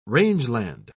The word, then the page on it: rangeland: British English